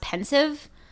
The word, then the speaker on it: Pensieve: Somebody wrote in to tell us it's pronounced pensive.